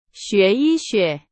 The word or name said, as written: 医学